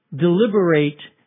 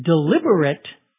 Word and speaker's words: deliberate: It's pronounced differently when it's used as an adjective. Deliberate has a long A at the end. The adjective deliberate has a short I sound at the end.